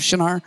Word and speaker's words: Shinar: You should know that Shinar, whether I'm pronouncing that wrong or right, is that right pronunciation of Shinar? Yeah. It's Shinar.